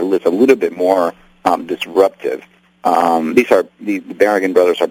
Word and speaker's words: Berrigan: Berrigan brothers, am I saying that correctly? – Yeah.